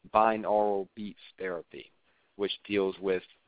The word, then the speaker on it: binaural: It's called binaural beats, and that's spelled B-I-N, A as in apple, U as in umbrella, R-A-L, binaural, like oral you hearing, your audio.